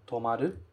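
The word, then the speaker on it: tomaru (止まる): Vou colocar o kanji, tomaru, significa parar, e a leitura é tomaru. A parte que está dentro do kanji, ela se pronuncia to, e o resto fica maru, do lado de fora.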